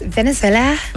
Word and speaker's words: Venezuela: Am I saying it right? Venezuela Fury. – No, you're saying it wrong. It's Venezuela.